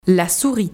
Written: la souris

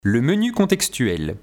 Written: le menu contextuel

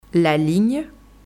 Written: la ligne

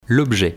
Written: l'objet

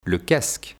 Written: Le casque

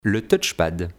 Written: le touchepad

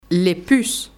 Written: les puces

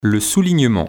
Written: Le soulignement

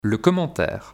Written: le commentaire

